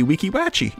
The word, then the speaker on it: Weeki Wachee: Is that how you pronounce it, though? – Weeki. Weeki. – Yeah, definitely. Weeki. Yeah. – Wachee. Wachee. I would say Weeki Wachee. – That's exactly what it is. Yeah.